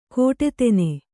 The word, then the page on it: ಕೋಟೆತೆನೆ: kannada